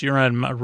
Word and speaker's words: Euron: We don't need your friggin' pogs and Euron. Plus, Euron rhymes with moron, kind of.